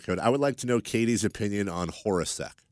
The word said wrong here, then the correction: Hornacek: Hornacek. – Yeah, I know. It's just Hornacek. How it's spelled.